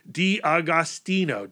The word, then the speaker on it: D'Agostino: inked by Ryan Thomas and John Diogo. how do you say that last name? John D'Agostino, D'Agostino, colored by Barry Grossman and lettered by Gary Fields.